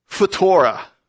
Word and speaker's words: phthora: And I found out there's a Greek word, it's phutora. Phutora. And, yeah, it's spelled P-H-T-H-O-R-A.